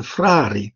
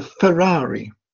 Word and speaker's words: Frari: But the thing that she couldn't do when she was 8 years old was to pronounce the name of the church, the Frari. She was always saying the Ferrari.